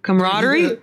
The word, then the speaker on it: camaraderie: Am I saying that right? Camaraderie? – Camaraderie. – I'm like, I don't know how to pronounce. – The faster you say it, the easier it is to pronounce. – Camaraderie.